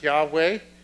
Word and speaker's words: Yahweh: And we pronounce that Yahweh. They actually were not totally sure how to pronounce it,